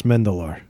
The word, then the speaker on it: Mandylor: it's spelled like Mandylor. The yeah? guy you know him if you saw him. You totally know him if you saw him. In the 90s, early 90s, this guy was showing up in everything. But the way he pronounced his name, he was like Kostas Mendelor.